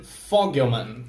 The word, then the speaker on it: Fogelman: E ele é dirigido por Dan Fogelman. Não sei se é isso a pronúncia correta. É esse mesmo.